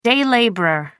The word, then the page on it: day labourer: American English